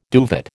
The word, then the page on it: duvet: American English